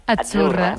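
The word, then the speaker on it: Azzurra: Achurra. Mira, ves, achurra. – Es que... – Achurra. Sí, como porque pizza. La Z no es la Z como decimos en catalán tan sonora, no.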